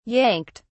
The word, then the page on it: yanked: British English